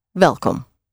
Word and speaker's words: welkom: So, again, welcome, spelled W-E-L-K-O-M. The W is pronounced like a V. Welcome.